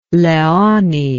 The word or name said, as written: leoni